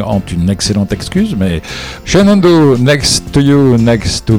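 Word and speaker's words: Shenandoah: Ça s'écrit Shenandoa, ça se prononce Shenando.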